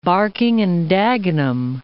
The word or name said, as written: Barking and Dagenham